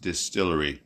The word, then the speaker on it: DSTLRY: It's spelled D-S-T-L-R-Y, but I believe it's pronounced Distillery.